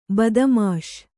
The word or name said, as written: ಬದ್ಮಾಶ್